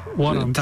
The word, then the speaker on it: Warrant: Trochę lepiej na czwartym, bo tam już zespół Warrant, tak to się wymawia? – Warrant.